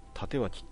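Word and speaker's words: 帯刀: で、 327、ランマ 2 分の 1 の、久能先輩でいいのかな、ランマ 2分の1 ももう忘れてるからね、自分微妙な年代なんでね、縦脇って読めますと。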